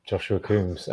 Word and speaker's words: Joshua Coombes: Honestly, you got it spot on, Joshua Coombes.